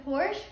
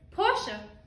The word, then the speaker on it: Porsche: Hello, did you know that the Porsche was founded in Germany in 1931? – Very good, but it's Porsche.